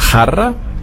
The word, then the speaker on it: Jarra: Dann hatten wir das J, da hatte ich das Beispiel mit dem großen Bier vom Fass, Jarra. Das J wird ausgesprochen wie ein CH, das vom Auch.